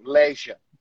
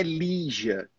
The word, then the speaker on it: leisure: Na primeira vez que você falou com um sotaque britânico, leisure. – Yeah. – Não sotaque, a pronúncia, né? Leisure. Mas para pronúncia americana é lija, lija.